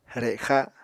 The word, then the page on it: rexa: Apatani